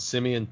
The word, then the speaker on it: Simeon: I've been watching on YouTube, Simeon Panda. His name is spelled like Simon, but it's Simeon with an E, last name Panda.